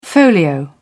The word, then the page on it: folio: British English